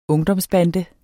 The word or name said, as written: ungdomsbande